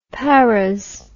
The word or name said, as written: paras